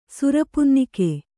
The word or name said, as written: ಸುರಪುನ್ನಿಕೆ